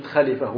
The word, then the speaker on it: خلفة: لاحظوا كلمة خليفة وهي الآن في اللهجة العامية هي خليفة فتح الخاء وكسر اللام، لكن في اللهجة العامية تنطق بإسكان اللام.